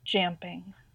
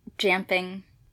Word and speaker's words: Jamping: Jamping. – Jamping. Spelled like jumping, but with an A instead of a U.